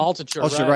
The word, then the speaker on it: Altucher: And James, is it Altucher? Is that how you say his name? – Altucher, right.